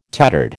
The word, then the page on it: tattered: American English